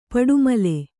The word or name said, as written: ಪಡಮೂಲೆ